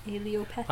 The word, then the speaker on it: allelopathy: Allelopathy. – I like the one you did better. – What did I just say? – Allelopathy. – Allelopathy. – Because it rhymes with homeopathy.